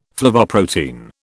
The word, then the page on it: flavoprotein: British English